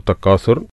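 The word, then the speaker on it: تكاثر: സൂറത്തു തക്കാസുർ, അതിലെന്തൊക്കെയാണ് നിയമങ്ങൾ നോക്കാം. അൽഹാക്കുമു തക്കാസുർ, തക്കാസുർ അഥ അലിഫു ബ ത ഥ അക്ഷരാണ്. അപ്പം ശരിക്ക് ഉച്ചാരണം ശ്രദ്ധിക്കുക. തക്കാസുർ സ എന്നുച്ചരിക്കരുത്.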